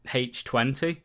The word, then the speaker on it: H20: Okay, so the second timeline, which was the first retcon, which happened 20 years after the original with Halloween H2O in 1998. It's probably meant to be pronounced Halloween H20, but I always say H2O for some reason. Because it's meant to be like 20, isn't it?